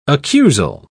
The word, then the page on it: accusal: British English